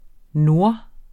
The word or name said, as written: nord